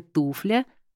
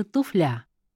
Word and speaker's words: туфля: Например, пресловутая «туфля» или все же «туфля». Чтобы запомнить, рекомендуют ассоциацию с формой множественного числа «туфли», «туфля». Ударение здесь нужно ставить на первый слог согласно орфоэпической норме современного русского литературного языка.